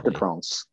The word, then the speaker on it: Parc des Princes: Parc des Princes.